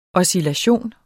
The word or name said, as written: oscillation